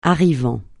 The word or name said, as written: arrivant